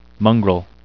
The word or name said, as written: mongrel